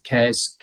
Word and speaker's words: CAERS: Um, CARES, K A E R S. So sorry, C A E R S. It sounds like caring, cares, but it's hmm. spelled with C A E R S.